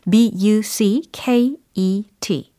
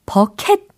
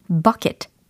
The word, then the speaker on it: bucket: 버켓 아니고 버킷 아니고 버 u 아, 강조를 했어요. 버 u 이름절 버 u 그리고 이음절에 있는 그 키트 발음이 아니고 캣. 캣. bucket 이렇게 약간 약화되는 발음이라는 거 이게 팁입니다.